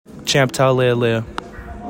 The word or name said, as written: CHAMP TAULEALEA